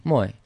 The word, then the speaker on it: Mooi: More with a hay. – Absolutely right. Yeah. – More hay. – Mooi, yeah. – Mooi. And then it's Mooi. So it's Mooi. – Mooi. – You got the accent on the mo.